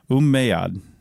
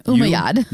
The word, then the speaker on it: Umayyad: What a double M means. – Umayyad. – Umayyad. I want to pronounce both M's. – I don't think you should. Umayyad. – U-M-M-A-Y-A-D.